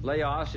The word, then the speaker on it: Laos: You mispronounced it. I have an accurate pronunciation here, Matt. – Oh, sorry. – Let's go ahead and play that. – My fellow Americans, Laos is far away from America, but the world is small.